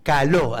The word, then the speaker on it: caló: Y conté que la palabra chamullo tiene una historia simpática, viene del lunfardo, y se la vincula con el caló, caló, acento en la O.